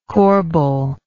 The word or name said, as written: corbel